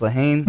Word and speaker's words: Lehane: It's a book by Dennis Lehane.